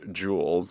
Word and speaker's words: Yule: The Norse celebration of Yule, which is pronounced as Jule,